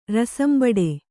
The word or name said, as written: ರಸಂಬಡೆ